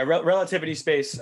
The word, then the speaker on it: Relativity Space: XPOA, you mentioned that there's rumors about being relatively space, or I probably mispronounced that. – Yeah, relativity space.